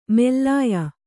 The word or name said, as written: ಮೇಲಾಯ